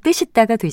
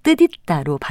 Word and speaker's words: 뜻있다: 여는 법칙을 적용해서 발음하면 뜻있다가 되지만 이 표현의 경우는 여는 법칙을 적용하지 않고 절음 법칙을 적용해서 뜻있다로 발음합니다.